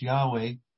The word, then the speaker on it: Yahweh: And so we don't actually know uh, for certain the exact pronunciation of the sacred name of God, but scholars uh, prefer um, the transliteration into English Yahweh.